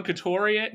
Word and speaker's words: Couturier: But you want to go into, like, the deals that were signed for, like, Svech and Kuteri? Oh my god, I can't say his name. – You want to try it again? – Kuteri? Kuteri? Sean Couturier? – There you go.